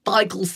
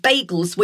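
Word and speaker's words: beigel: But then later the consistency changed and they became the beigels that we know today. But now people tend to say bagels, which is more of an American pronunciation.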